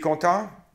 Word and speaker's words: content: Content. Don't hear the T.